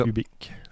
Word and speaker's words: hubiC: Et ça s'écrit H-U-B-I-C. Oui, c'est ça. Ubic.